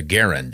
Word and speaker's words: Garand: or the Garand as it's pronounced, I believe.